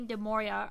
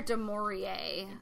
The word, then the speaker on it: du Maurier: I hope I'm pronouncing her name right. She's a very classic. – It's either du Maurier. – Du Maurier, okay. Katie may be a little bit better with the beautiful, flowery, European-sounding Maurier.